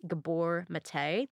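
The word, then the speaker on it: Gabor Maté: but I believe it's pronounced Gabor Mate.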